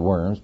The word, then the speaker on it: Worms: If you were to go to Germany, Worms, it's spelled Worms, but it's pronounced Worms, Germany today, you would find this statue in the city.